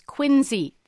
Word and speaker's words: quinzhee: It's quinsy, the term for a snow shelter. – How do you spell that? – Q-U-I-N-Z-H-E-E.